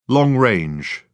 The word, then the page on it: long-range: British English